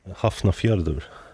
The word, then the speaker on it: Hafnarfjörður: And by 10 past six in the morning, the car is at Halfnarf Harbour. I can show you that, because I actually do have it written down, and that's probably why I'm pronouncing it so badly. This? – Hafnarfjörður.